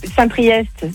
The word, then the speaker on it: Saint-Priest: Saint-Priest, c'est EST, tu le prononces.